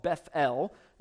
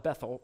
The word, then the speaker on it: Beth-El: He prophesied to the northern kingdom of Israel, and the northern kingdom had a temple at a city called Beth-El, which again, that's, we call it Bethel, right? But when you pronounce it that way, Beth-El, Beth means house, El means God, so Beth-El is the house of God, right?